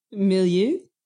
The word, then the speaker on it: milieu: Milieu? – Milieu? – Sure. – It's très French, you know? Milieu.